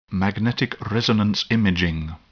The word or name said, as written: magnetic resonance imaging